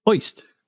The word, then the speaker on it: Ojst: And they had his name O J S T, and it's pronounced Oist.